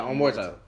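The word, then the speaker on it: Omertà: Is it Omerta? I keep saying that wrong. Omerta. – Oh yeah, Omerta. Yeah, Omerta. So that, on that song, this man literally said, he was like, niggas got hot because they told on me.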